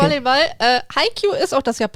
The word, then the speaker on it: Haikyuu: Na, du hast es schon mal ausgesprochen, das heißt Haikyuu. – Yay! – Sonst hätte ich das nicht gewusst, aber so habe ich das schon mal gehört, genau. – Schön. Genau, der Manga heißt Haikyuu.